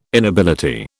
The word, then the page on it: inability: British English